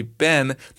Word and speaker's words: been: He sings, Eleanor Rigby picks up the rice in the church where the wedding has been, because he's American and Americans tend to say Ben. That doesn't really rhyme with lives in a dream, as well as the English pronunciation of where a wedding has been rhymes with lives in a dream much better.